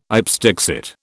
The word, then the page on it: ipse dixit: British English